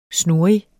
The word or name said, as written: snurrig